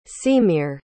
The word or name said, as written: seamere